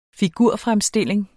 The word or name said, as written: figurfremstilling